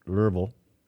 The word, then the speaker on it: Louisville: And my friends tell me that's pronounced Louisville, Kentucky, for not to exceed $860 million.